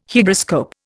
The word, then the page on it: Hygroscope: Indian